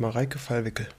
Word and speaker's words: Mareike Fallwickel: Du hast den Namen richtig gesagt, Mareike Fallwickel.